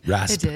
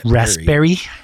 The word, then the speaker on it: Raspberry: Raspberry. – It did. Raspberry. – You forgot the B. – Yeah. Oh. – Raspberry. – Oh, Raspberry. Did he spell it without the B? – No, he said it without the B.